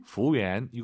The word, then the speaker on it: 服务员: 服务员. 이거 우를 빼고 한번 발음해 볼게요. 服务员, 服务员. 빠르게 발음하시면 더 맛깔나는 중국어가 될 것 같습니다.